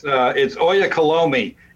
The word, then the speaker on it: Oyakhilome: it's Oyakhilome, and it's taken me 5 years to get to that point of letting it just kind of roll off my tongue.